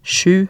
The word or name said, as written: sju